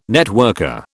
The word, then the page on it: networker: British English